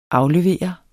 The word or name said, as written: AFLEVER